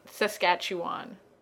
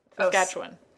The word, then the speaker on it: Saskatchewan: But lots of people not from that region say Saskatchewan when it's not. It's Saskatchewan.